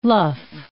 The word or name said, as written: luff